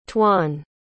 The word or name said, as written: twon